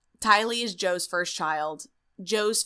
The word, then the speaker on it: Tylee: Tylee. – T-Y-L-E-E.